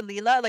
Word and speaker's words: Lila: It's pronounced Lila or Leela, like, in case people are wondering, like, why we're saying it two different ways.